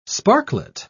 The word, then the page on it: sparklet: British English